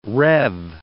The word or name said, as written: rev